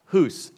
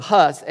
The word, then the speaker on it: Hus: 100 years before him was a man named John, and it's pronounced Hus, H-U-S. It's been anglicized into Huss, H-U-S-S.